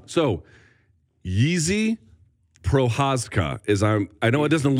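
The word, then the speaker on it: Jiří Procházka: It's spelled J-I-R-I, but it's got all kinds of squiggly lines on the top of it. I am told it is pronounced Yizi Prohazka.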